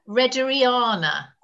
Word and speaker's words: Rehderiana: Now this is a clematis, I can never say it right, Rederiana, R-E-H. Yeah, R-E-H-D-E-R-I-A-N-A.